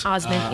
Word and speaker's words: Osment: For the casting, other than I think Haley Joel Osment, is how you say it? Osment? – Osment, yeah.